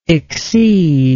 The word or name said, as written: exceeder